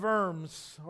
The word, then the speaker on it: Worms: I don't know how many worms you would actually eat. But it's a trial in the city of Worms, or Worms. They pronounce the W as a V in German.